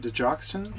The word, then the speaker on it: digoxin: How do you say it? Digoxin. – Yeah.